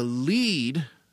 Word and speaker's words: Lied: And on February 11th, in Lincoln, Nebraska, at the Lead Center. It's spelled L-I-E-D, but it's pronounced L-E-E-D.